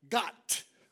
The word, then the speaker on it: Gott: And Gott, right, is how it's pronounced.